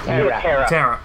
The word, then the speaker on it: Tara: And on the bench, Eric Tovenin, Kenny DeMarcus, Kasper Kusik, Nikissa Kessis, Akiat Pratt, and Tia Tarea. – Tara. Tia Tara. One syllable.